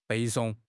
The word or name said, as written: 背诵